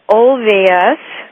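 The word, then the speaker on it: Olweus: And Marlene is the director of the development of the OLVAS. Marlene, did I say that correctly? – You sure did.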